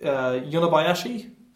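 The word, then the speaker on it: Yonebayashi: We looked at it before, but it's I'm gonna pronounce this wrong. It Yonabayashi. – That sounds right.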